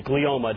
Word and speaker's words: glioma: a malignant glioma.